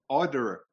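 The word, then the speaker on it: Oedekerk: You're mispronouncing his name. That's not his name. You're giving him too much credit. Steve Odekirk. – No, it's Odekirk.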